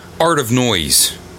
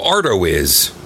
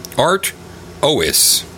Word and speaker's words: Artois: I'm going to say Stella Art of Noise. Oh, Artois. Artois.